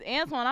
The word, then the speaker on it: Antoine: So if you be like, my last name is Antoine, I'm going to be like, who your people is? – But sometimes like, there was somebody.